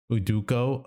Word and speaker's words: Udoka: Udoka. – Udoka.